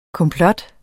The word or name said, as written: komplot